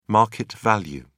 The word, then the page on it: market value: British English